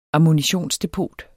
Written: ammunitionsdepot

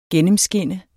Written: gennemskinne

